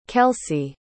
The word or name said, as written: kellsie